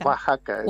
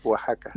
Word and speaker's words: Oaxaca: Oaxaca, no sé si lo dije bien, Oaxaca. – Oaxaca. Oaxaca, es más como una J, Oaxaca.